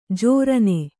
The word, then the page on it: ಜೋರನೆ: jōrane